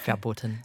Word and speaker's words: Verboten: Um, you're a big name on the fetish party scene as founder of Club Verburton. Is that how you say it? – Almost, yeah. – go on. How do you say it? – Verboten. Verboten.